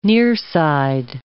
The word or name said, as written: nearside